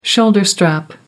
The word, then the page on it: shoulder strap: American English